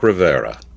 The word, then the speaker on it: Rivera: How would you pronounce his last name? – Mark Rivera. – Rivera, sorry. – Like Geraldo Rivera.